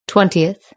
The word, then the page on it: twentieth: British English